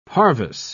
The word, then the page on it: parvis: British English